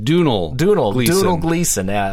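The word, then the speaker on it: Domhnall Gleeson: And through a contest in his company, he has come stay with him, one of his employees, played by, we decide how we pronounce his name? – I think Dunal Gleason, Brendan Gleason's son, who is also starring in everything at the moment.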